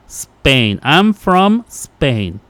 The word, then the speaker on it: Spain: Spain. – Spain, dice la S nada más.